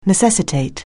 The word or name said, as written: necessitate